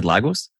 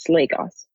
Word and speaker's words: Lagos: You said Lagos? – Yes, Lagos. – Lagos. Sorry, mispronounced that.